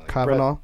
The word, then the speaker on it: Kavanaugh: Kavanaugh. Did I pronounce that right? – Kavanaugh.